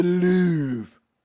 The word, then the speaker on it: Louvre: The Louvre. I just like how to say the Louvre. L-O-U-V-R-E.